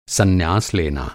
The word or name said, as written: सन्यास